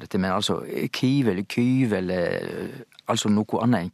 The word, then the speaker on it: Kyiv: Og det betyr at det er Ukraina-krisa, og det fører oss til Kiev. Ja, hovedstaden der heter Kiev, og det er det vi er vane med å si på norsk. Det er den russiske uttalen for den ukrainske, og dette er jo, nå er jo Ukraina et selvstendig land.